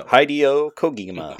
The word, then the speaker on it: Hideo Kojima: Hideo Kojima.